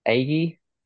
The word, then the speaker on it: Agee: And then there's obviously, I met Steve Agee, if yes. – that's how you pronounce his name.